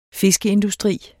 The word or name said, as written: fiskeindustri